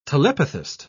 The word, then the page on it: telepathist: British English